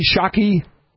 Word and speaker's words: Psaki: I'm just going to say, kind of like I do with Jen Psaki, Shockey.